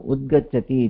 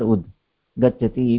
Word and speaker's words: उद्गच्छति: एवम् अत्र उद्गच्छति इत्यत्र उद् गच्छति इति मा वक्तव्यम्. उद्गच्छति इति तत्र उच्चारणविषये अस्ति महोदय.